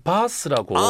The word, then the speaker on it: Bath: 런던에서 한 2시간 반 가면 바스라고.